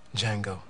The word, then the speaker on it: Django: Django. – The D is silent.